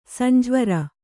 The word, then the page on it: ಸಂಜ್ವರ: sanjvara; kannada